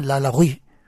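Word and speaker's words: Lalaurie: Sí, la de Madame Lalaugie. – Sí, correcto, qué bien pronunciado.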